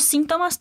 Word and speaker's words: síntomas: Después de regresar, el chico sigue preguntándole, ¿cuáles otros síntomas tuviste? Y aquí quiero llamarte la atención para el énfasis de la palabra síntoma, que a diferencia del portugués, la ponemos en la primera sílaba. – Repite, síntomas. Síntomas.